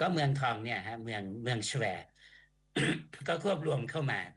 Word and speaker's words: ชเว: 1816 นะครับแล้วก็ควบเมืองคนพื้นถิ่นเขาออกเสียงเป็นสแวร์จริงๆผมเชื่อว่ารากนี้มันมาจากคำว่าชเวซึ่งเป็นภาษาหมอนแปลว่าทองคำที่พม่าออกเสียงว่าสุย